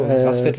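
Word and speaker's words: PACCBET: Rasvet wird es, glaube ich, ausgesprochen, Packbett geschrieben irgendwie.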